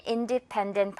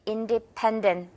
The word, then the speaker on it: independent: I'm an independent person. I'm an independent person. Alright? 이렇게 세 번째 음절에 강세 주기.